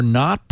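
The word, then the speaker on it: Knot: which were not protecting their queries from this cyclic DNS misconfiguration.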